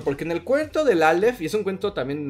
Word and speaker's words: Aleph: Aleph, ¿no? – El Aleph. – No, ese es como, que de hecho es de sus obras más. Es que se escribe Alep, PH, Alep, pero bueno, se dice Aleph.